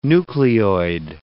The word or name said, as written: nucleoid